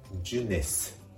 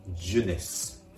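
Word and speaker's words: jeunesse: 末の S の発音。これをしっかり芯のス、スとして発音してあげるところがポイントです。ジュネス、ジュネスですね。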